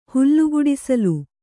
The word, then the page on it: ಹುಲುಗುಡಿಸಿಲು: Kannada